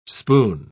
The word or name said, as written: spoon